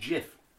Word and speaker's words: GIF: Do you know gifts meant to be pronounced GIF? – I thought it was SIF now. – That's Euro. – Joke, mate. But it is meant to be GIF, but we've all got used to the hard G.